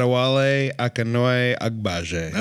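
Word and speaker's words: Adewale Akinnuoye-Agbaje: Adewale Akinnuoye Agbaje.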